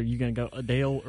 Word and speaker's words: Adele: No, apparently, the correct way to say Adele's name is Adele. – No, it's Adele. – As opposed to the more popular pronunciation of Adele. – Uh-huh. – Now, if you have her British accent, I can see where it would come out, Adele. But... – Well, see, what's interesting is my grandfather, who was British, it was Adele. – It was emphasis. It was heavy on the first syllable.